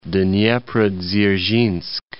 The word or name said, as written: Dneprodzerzhinsk